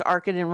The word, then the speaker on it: Arkadin: And did I pronounce Arkadin right, Carl? Because it's based on the Citizen Kane reference. – Yeah, I believe that is correct.